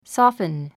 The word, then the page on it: soften: sɔ́ːfən; ソォッフン